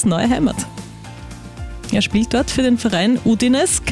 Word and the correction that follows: Udinese: Calcio, oder habe ich das richtig ausgesprochen, Udinese. – Simon? Udinese Calcio, gut, er ist jetzt dort offiziell präsentiert worden.